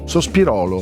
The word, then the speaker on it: Sospirolo: Tranquilli, lo state pronunciando giusto. La gente si confonde con Sospiro in provincia di Cremona. C'è anche Sospiro in provincia di Cremona. – Ah, che bello. – Madonna, ma questo è il paese, di, paese dei sospiri. – Sospirolo. – Sospirolo. – Sì, è Sospirolo. – Sospirolo. Okay. L'accento Spirolo. È giusto. Sospirolo.